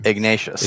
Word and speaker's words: Ignatius: Ignatius.